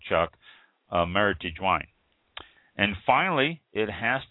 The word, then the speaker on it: Meritage: And shouldn't be pronounced as if it were French, because it's not a French name. It's Meritage.